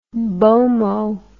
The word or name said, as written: bomoh